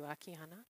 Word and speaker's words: Akiana: Thank you, Akiana.